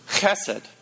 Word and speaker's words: chesed: You got to spit it. Chesed.